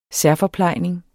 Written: FORPLEJNINGEN

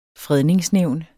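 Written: FREDNINGSNÆVNET

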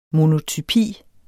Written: monotypi

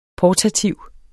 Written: portativ

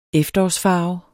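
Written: efterårsfarver

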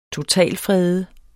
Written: totalfrede

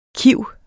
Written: kiv

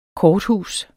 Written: korthus

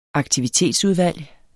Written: aktivitetsudvalg

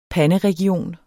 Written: panderegion

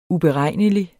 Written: uberegnelig

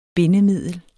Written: BINDEMIDLET

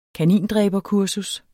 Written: kanindræberkursus

